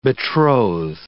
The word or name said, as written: betroth